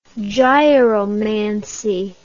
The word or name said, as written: gyromancy